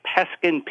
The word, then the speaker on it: Peskin: It's my name, Brian, B as in boy, R-I-A-N. Last name is Peskin, P as in park, E-S-K-I-N.